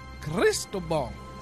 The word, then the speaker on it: Cristobal: Cristobal. – See, look, I was saying it wrong, so I stopped and looked over. – Cristobal from Oregon.